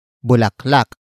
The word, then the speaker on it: bulaklak: And that is bulaklak. Bulaklak. Bulaklak. Bulaklak is spelled as B-U-L-A-K-L-A-K.